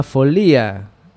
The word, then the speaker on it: folía: Esto es la folía, no la folia ni la folía. – La folía. – La folía, que lo pronunciamos mal.